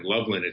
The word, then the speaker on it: Lovelian: But yes, these two defendants, Rick Lovellian, L-O-V-E-L-I-A-N, Loveland, it's pronounced, and Stephen Stewart were part of the whole mass of defendants that were indicted maliciously by the U.S.